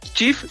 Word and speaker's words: Cheaf: Chief, que se escribe CHEAF, CHEAF, partió